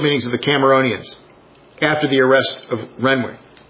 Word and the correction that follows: Renwick: And I've heard it's pronounced Rennick, so I've been pronouncing it wrong for 2 weeks. It's Rennick.